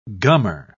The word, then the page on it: gummer: British English